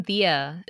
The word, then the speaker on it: diya: Do I want to make sure I pronounce this correctly? Diaz? – Yeah, make your own diya.